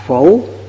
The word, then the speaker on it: phủ: Một chữ sau cùng này, âm cổ đọc là phủ, chữ phủ của thị phủ, tức là phải hay không?